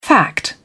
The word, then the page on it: fact: British English